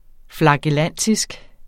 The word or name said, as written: flagellantisk